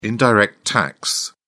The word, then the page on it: indirect tax: British English